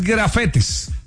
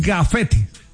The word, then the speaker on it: gafete: No, porque no es grafetes. – Ah, ok. – Es gafete.